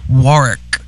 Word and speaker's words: Warwick: There's another W there, but you don't pronounce it. It's Warwick. – Warwick. Warwick. Are you? I'm getting a lesson. W- I'm getting a lesson in talking from somebody Warwick. From the UK. Warwick. – Yeah, just because Dionne Warwick wants to be called Dionne Warwick, she just don't know how to pronounce the name Warwick. It's Warwick. It's a silent Warwick. W. It's English.